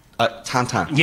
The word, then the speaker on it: Tintin: I'm going to give you an extra point if you can say Tintin in the Belgian pronunciation. – Tintin.